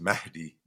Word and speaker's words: Mahdi: So really the pronunciation is Mahdi, but in English, we don't pronounce any H sounds in the middle of words, right?